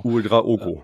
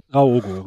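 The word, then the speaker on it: Ouédraogo: Und äh, für Scheinbeck ist äh, UE3 Ego draußen geblieben. Genau. – Draogo, äh, so. Draogo, richtig. Draogo, Mann. – Mit Betonung auf dem E.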